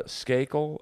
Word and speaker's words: Skakel: Skakel? How do you spell that? S-K-A-K-E-L.